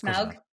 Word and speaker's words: Smaug: I'm never going to say it right. Did I say it right? – Yeah, it's Smaug. Yeah.